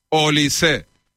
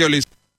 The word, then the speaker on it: Olise: es Olise. Según Pablo Polo, Olis, el que controla francés, dice que se pronuncia Olis. Aquí en España le llamamos Olise, porque nos sale del forro.